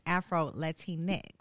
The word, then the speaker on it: Afro Latinx: Andrea, because I know you're a first gen Afro Latinx, right? – Yes.